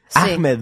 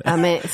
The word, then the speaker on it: Ahmed: El actor Ahmed, Ahmed Best Ahmed Best, ¿cómo se dice? Ahmed. Ahmed, Ahmed, Best.